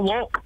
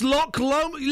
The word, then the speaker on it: Loch Lomond: It's not a lock. – It's Loch Lomond.